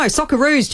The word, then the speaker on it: Socceroos: So she's called, yeah, the Socceroos Securus. And it was not missed. Poor love. I know. Yeah, poor Jane. Jane won't live that down. Securus. No, Socceroos, Jane. Socceroos. But anyway, look, we do.